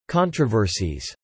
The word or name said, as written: controversies